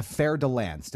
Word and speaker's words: Fer de Lance: but it's a Fer de Lance.